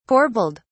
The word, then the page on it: corbelled: British English